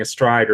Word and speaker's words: Stryd: So like you're doing a stride or striders, like the fast running after a workout. But then if you spell it weird, it still sounds the same, but it's STRYD, which actually I think in like Welsh or Afrikaans, it means street.